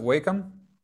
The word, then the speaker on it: Wakeham: Miles, how do you pronounce it? Wakeham? – Wakeham, yes, thank you.